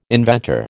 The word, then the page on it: inventor: American English